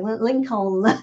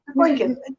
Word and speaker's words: Lincoln: You know, he lost most of his accent, but whenever he said Lincoln, he always pronounced the second L. It's like Lincoln. It's like, that's the one thing, like, I was like, you know, Dad, it's Lincoln.